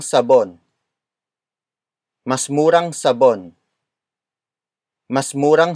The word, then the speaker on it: murang: Murang. Murang. Murang is spelled as M-U-R-A-N-G.